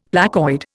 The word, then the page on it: placoid: Indian